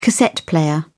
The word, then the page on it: cassette player: British English